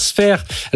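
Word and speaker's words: Sfeir: Sphere, alors pour ceux qui nous écoutent à radio, ça s'écrit S-F-E-I-R. C'est bien ça.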